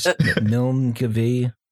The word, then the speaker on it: Milngavie: Milngavie. – Inverness. – Inverness. Yeah. – Yeah, Milngavie is good, actually. To be fair, it's not as bad as I've heard, actually.